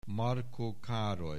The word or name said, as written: Marko Karoly